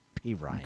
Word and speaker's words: Perine: Yeah, Perine.